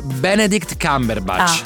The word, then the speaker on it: Benedict Cumberbatch: In primis Benedict Cumberbatch, se l'ho detto bene. Perdonatemi. – No, guarda, allora, forse si dice Benedict Cumberbatch.